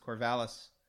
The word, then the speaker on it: Corvallis: Let's move on up to Corvallis correctly pronounced Corvallis, where Auburn or Eagle, but they're the Tigers.